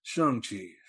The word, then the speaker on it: Shang-Chi: Some people have really been on this movie, like virtue signalers have been correcting people on the internet saying it's pronounced Shang-Chi. Shang-Chi, you have to say it correctly.